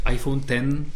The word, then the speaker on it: iPhone X: No ale to se nevyslovuje X, to se no. vyslovuje iPhone 10 Česky teda 10.